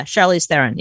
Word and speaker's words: Charlize Theron: Charlize Theron. Is that how you say your name? – Yeah. Charlize Theron.